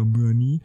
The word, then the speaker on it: Muani: Und die hat dann auch nämlich einfach am Anfang gefragt und gesagt, ey, wie spricht man den denn aus? Und dann wurde er von dem Pressesprecher oder weiß Gott oder von ihm selbst so also gesagt, so ja, Mühe nie.